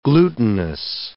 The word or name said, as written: glutenous